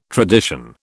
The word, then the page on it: tradition: British English